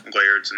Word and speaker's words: Laird: And then we go to Patrick Lard, Miami's running back, or Miles Sanders. I feel like that's not how you pronounce Patrick Lard. Laird's name. Laird's, whatever.